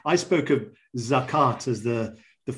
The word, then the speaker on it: zakat: Um, you're using, I suppose, the, the, the Arabic pronunciation zakat, which of course in compound form becomes zakat.